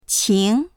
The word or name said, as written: qing2